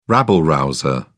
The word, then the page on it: rabble-rouser: British English